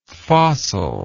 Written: fossillike

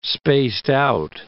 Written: spaced-out

